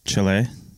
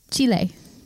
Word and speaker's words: Chile: And isn't it Chile? – Chile, if you want to like Chile. Have a Spanish pronunciation.